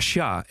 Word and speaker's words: Shah: Jigar Shah, S-H-A-H. Kijk, dat spreek je mooi uit.